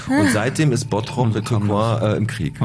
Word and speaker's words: Tourcoing: Ja, Tim, Tourcoing, richtig.